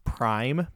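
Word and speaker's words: PRhyme: And then I believe it's pronounced Prime, but it's spelled like P Rhyme rapper.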